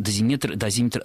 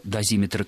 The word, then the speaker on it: дозиметр: Ну, прежде всего, скажу сразу, что дозиметр только радиацию измеряет, поэтому не нужно добавлять второе слово. И дозиметр в большинстве словарей, как толковых, так и орфоэпических, дается только с одним ударением, ударением на второй слог.